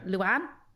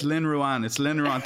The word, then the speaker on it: Lynn Ruane: They automatically put the Lynn and the Ruane together and say, Luan? No, Lynn.